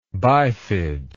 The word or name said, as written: bifidly